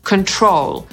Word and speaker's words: control: Kontrolować to po angielsku control, czyli akcentujemy drugą sylabę.